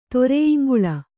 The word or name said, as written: ತೊರೆಯಿಂಗುಳ